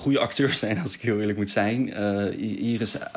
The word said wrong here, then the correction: Idris: Elba, ik kan even zijn naam niet goed uitspreken. – Idris, die vond ik zelf niet de beste keuze.